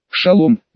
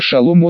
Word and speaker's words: шалом: Да еще и слово «шалом» он произнес с ударением на первый слог.